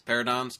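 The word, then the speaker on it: Pteranodon: Pterodons, pterodons. – With a P? – No, with a T. Starts with P, yeah, but you pronounce the T. Exactly. It's a silent P.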